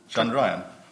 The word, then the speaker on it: Chandrayaan: Chandrayaan.